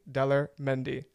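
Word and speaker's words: Daler Mehndi: So here's Tunak Tunak by Dalair Mendy. I hope I pronounced that correctly. Here it is. – Dalair Mendy. – Yes. Thank you.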